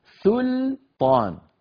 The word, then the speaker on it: سلطان: أيضا عندنا الآية 68، 68، قوله عز وجل إن عندكم من سلطان بهذا، سلطان، سل، هذه السين واللام مرققتين، سل، سل، ثم نقول طا، سل، طا، فنرقق السين واللام ثم نقول طا وننطق الطاء والألف اللي بعدها.